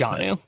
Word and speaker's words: Jonnu: Yeah, it's John just Jonnu. It literally is just John with a U at the end of it. – Okay. Jonnu Smith would be the other guy that I would be interested in.